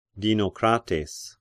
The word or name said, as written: Dinocrates